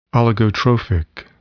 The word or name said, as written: oligotrophic